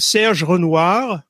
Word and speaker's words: Serge Renouard: It's Serge Renouard, right? – Perfect.